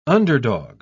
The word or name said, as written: underdog